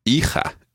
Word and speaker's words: hija: Hija. Excellent. And there's a silent H in there at the beginning.